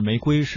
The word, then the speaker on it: 玫瑰: 刚才啊，这个说到玫瑰啊，这它的正常发音是玫瑰，是最后那个瑰是医生啊。各位不要被我们刚才那个轻声误导，它没有贵这个音啊。